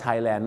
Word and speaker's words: Thailand: ที่จะออกเสียงคำว่า land นั่นก็คือ 1 Thailand ถึงแม้ว่าบางคนจะออกเสียงว่า Thailand นะครับแต่ส่วนมากเราออกเสียงว่า Thailand มากกว่า Thailand